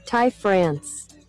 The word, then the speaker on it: Ty France: Ty France.